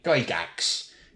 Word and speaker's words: Gygax: I would pronounce it correctly, Gygax. Gary Gygax. Not Gygax, not Gyjax. He is Gary Gygax. And I can tell you this because I have heard him say it.